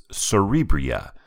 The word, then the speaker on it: Cerebria: It is Cerebria, that's spelled C-E-R-E-B-R-I-A, by Ricard Amon and Victor Pater, published by Mind Clash Games with 38,075, or nearly 7,000 more than Massive Darkness.